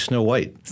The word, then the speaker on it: Snøhvit: I don't know if I'm pronouncing these correctly, Sleipner and Snøhvit. I don't know if I'm saying it right. Completely wrong. – No, that's very good. And actually, I was saying Snøhvit too. It's actually Snow White.